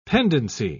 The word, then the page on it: pendency: British English